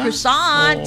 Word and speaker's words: croissant: Croissant.